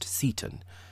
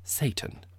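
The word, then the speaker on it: Seyton: His name is probably pronounced Seton, but there's another way of saying it. If you prefer, you could pronounce it Satan, which seems rather startling.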